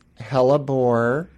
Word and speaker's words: hellebore: Okay, so I have to say, so a hellebore, am I saying that correctly? – Yes. – Hellebora or hellebore? – It's hellebore.